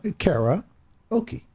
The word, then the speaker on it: karaoke: Karaoke. – K-A-R-A-O-K-E. Yep. – Okay. – But you don't say it like that, so that's why it's confusing.